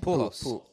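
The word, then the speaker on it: Pujols: They say, is that how you say his name? – Pool halls. – Yeah, you're good. It was just pool halls. – It sounded like you said pool, like a pool hall.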